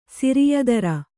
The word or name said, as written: ಸಿರಿಯದರ